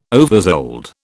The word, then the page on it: oversold: British English